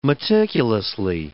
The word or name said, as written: meticulously